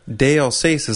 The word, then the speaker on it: d'Alsace: Café d'Alsace, as we said, he said it's pronounced Dalsace. – Right, and I've heard a bunch of different pronunciations, like Dalsace, Dalsace, Dalsace. So we'll say Dalsace from now on.